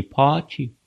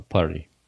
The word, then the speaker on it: party: Буква Т. Становится звонкой, и она уже не «парти» читается. И не «пати», как в Британии сказали бы, а «парти».